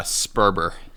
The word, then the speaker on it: Sperber: How do I pronounce, by the way, how do I pronounce your last name? – Yeah, Sperber. – Sperber.